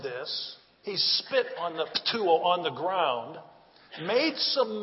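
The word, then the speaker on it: ptuo (πτύω): It is ptuo. Is that not cool or what? If you want to spell it in English, it's P-T-O-U, ptuo.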